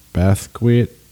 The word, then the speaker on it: Basquiat: Basquiat. – Yeah. – Oh, but I'm going to have stories for you about the actual Jean-Michel Basquiat next week.